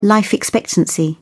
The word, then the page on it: life expectancy: British English